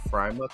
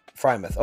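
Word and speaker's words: Freiermuth: And then Pat Furlemuth, I want to say that's how it's pronounced. God, I don't – some of these names are weird. – I think it's Frymuth. – Frymuth. Okay, cool. We'll go with that then.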